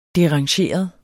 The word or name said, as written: derangeret